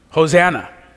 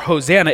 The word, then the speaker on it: Hosanna: Now kind of rush those syllables together. Hoshiana, Hoshiana. Do you hear it? Hoshiana, Hosanna.